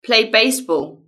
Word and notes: play baseball: British English

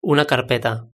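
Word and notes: una carpeta: Spanish (Spain)

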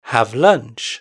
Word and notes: have lunch: British English

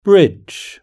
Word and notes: bridge: British English

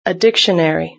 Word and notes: a dictionary: British English

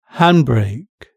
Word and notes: handbrake: British English

